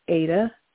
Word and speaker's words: Ada: My middle name is Ada, A-D-A.